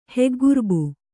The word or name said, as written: ಹೆಗ್ಗುರ್ಬು